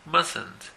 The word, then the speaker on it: mustn't: El mustn't, la T, la primera T no se pronuncia, mustn't.